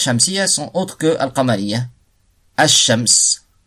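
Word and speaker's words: ash-shams (الشمس): Parce que ash-shams, on dit pas al-shams, ash-shams.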